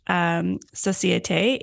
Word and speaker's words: Société: the hosting society community, you'll notice that it's spelled in French, Société. If you want to pronounce it in French, that's fine with me, but I also call it the hosting society.